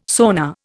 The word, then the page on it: sonar: Indian